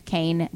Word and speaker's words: Koehn: And I'm on Instagram at Sandra Kane, D-O. Kane is spelled K-O-E-H-N. Like Cohen.